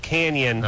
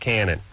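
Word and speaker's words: Cañon: It is pronounced Canyon, okay. But they call it Cannon. – I see. Okay, got it. – It has an N-A. – Yeah, it has the senior squiggle over the N, and it should be pronounced Canyon, but everyone always called it Cannon, and that's what they still do.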